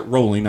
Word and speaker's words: Rowling: They pronounce it Rowling. I didn't know that either. It could just be their British accents.